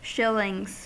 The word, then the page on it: shillings: American English